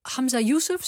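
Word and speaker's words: Humza Yousaf: Dan is er een nieuwe premier, Hamza Youssef, zeg ik het goed? – Ja.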